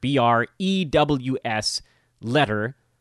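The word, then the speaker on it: Brewsletter: Bruised Letter spelled like newsletter with BR at the beginning. So don't change any of the other letters in the word. BREWS letter 2021.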